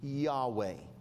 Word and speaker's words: Yahweh: Actually, in Hebrew, there's no vowel. It's YHWH. But it is pronounced Yahweh. If you could think of a Y-A-Y-W-E-H, something like that, that's or Y-A-W-W-E-H kind of a thing.